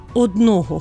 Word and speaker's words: одного: Наприклад, бачу одного хлопця або бачу один зошит. Орудний відмінок. Одним, місцевий, на одному. Або на однім. Наголос у непрямих відмінках числівника «один» припадає, звичайно, на закінчення «одного», «одному».